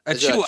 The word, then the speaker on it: Achiuwa: Achiuwa.